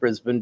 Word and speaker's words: Brisbane: which I know is pronounced Brisbane because of you. – Brisbane. – It looked like Brisbane to me.